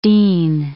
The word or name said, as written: dene